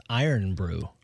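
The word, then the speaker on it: Irn-Bru: Apparently, it's pronounced Iron Brew. – Iron Brew. You know, if I was going to create a beverage and wanted it pronounced like that, I would have spelled it that way. – I-R-N.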